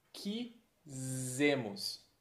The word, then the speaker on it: quisemos: Muita atenção para a diferença de pronúncia entre o S do quis no singular e o som de Z no plural. Então, eu quis, nós quisemos.